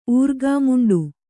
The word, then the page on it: ಊರ್ಗಾಮುಂಡು: Kannada